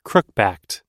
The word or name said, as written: crookbacked